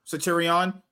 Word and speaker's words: Centurion: How you pronounce that? – No, Centurion.